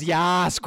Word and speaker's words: Yas: You didn't even say it right. You just said yes. Extend not even yes. Okay. I think it's Y-A-S-S-S. – It is. It's Yas Queen. – Yas. Much better.